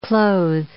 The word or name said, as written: clothe